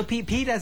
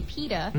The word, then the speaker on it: PETA: Because some say pita, some say peta. – Yeah. – So pita peta doesn't really matter.